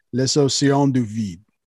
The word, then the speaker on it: Les Océans du Vide: Apparently, it's pronounced Les Océans du Vide. – Word up. – Yeah, it's French.